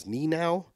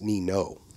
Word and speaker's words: Nienow: This is from somebody who's written us before, Sam, and I have previously pronounced his last name as Nienow, because it's spelled Nienow, but he informs me in the PS in this email that it's pronounced Nino.